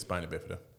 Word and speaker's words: spina bifida: spina bifida. If I'm saying that correctly, I don't know. – It is spina bifida.